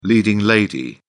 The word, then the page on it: leading lady: British English